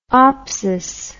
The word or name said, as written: opsis